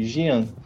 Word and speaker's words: Gian: Tá ele, usa uma, ele fala um, um jutsu que é Gian, que se escreve, da mesma forma se escreve o meu nome, Gian. Fala Gian.